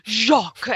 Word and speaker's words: Jacques: And now you can't say the name without using a French accent. Jacques. And apparently last week I pronounced it right.